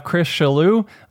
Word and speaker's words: Chris Shallue: And then we have Chris Shallue. Am I saying that right, Chris? – Yes, that's correct.